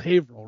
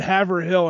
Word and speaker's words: Haverhill: So Haverhill is spelled Haverhill, but it's called Haverhill, right? So yes. – She said Haverhill and I'm like, nope, can't do it.